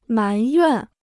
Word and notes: 埋怨: mán yuàn

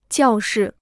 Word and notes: 教室: jiào shì

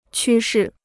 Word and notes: 趋势: qū shì

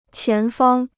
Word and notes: 前方: qián fāng

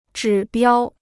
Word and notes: 指标: zhǐ biāo

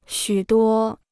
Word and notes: 许多: xǔ duō

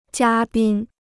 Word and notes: 嘉宾: jiā bīn

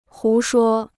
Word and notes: 胡说: hú shuō

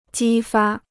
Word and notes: 激发: jī fā

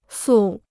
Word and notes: 素: sù

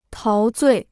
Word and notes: 陶醉: táo zuì

